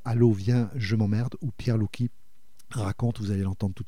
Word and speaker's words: Pierre Louki: Alors Pierre Louki, ça s'écrit L-O-U-K-I,